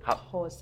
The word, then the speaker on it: Hausa: I'm curious about your dialogue as far as the foreign language, because I heard you speak a little Hausa. I mean, was it? – Hausa.